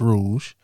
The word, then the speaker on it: Rouge: You know, it ain't Rogue, y'all. It's Rouge. Now, now where say what? – I said like the color.